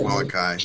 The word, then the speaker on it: Molokai: And that's Molokuai. Molokuai. I think that's how you say it. That's Maui. With the Molokai. Molokai. – Moon over Molokai.